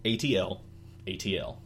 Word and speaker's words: atlatl: It is spelled A-T-L. A-T-L. – Okay. – I always thought it was at-latl or at or at-latl.